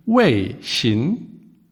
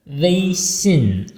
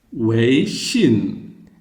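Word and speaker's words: Weixin (微信): WeChat auf Chinesisch Weixin. – Diesmal war die Geste auch falsch. Also Weixin, einmal flach, einmal unten. – Weixin.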